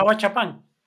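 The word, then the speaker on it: Ahuachapán: And you mentioned where your father's from, Ahuachapan. Am I pronouncing that correctly? – Yeah, Ahuachapan.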